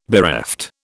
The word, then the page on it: bereft: British English